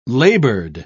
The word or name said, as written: labored